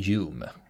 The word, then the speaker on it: Home: Det här namnet alltså, det stavas Home, men det uttalas mm. Hume. – Mm, som filosofen.